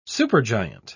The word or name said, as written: supergiant